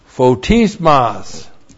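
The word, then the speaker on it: photismos (φωτισμός): And then photismos. P-H long O-T-I-S-M-O-S. Photismos.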